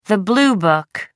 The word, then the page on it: blue book: American English